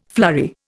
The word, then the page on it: flurry: Indian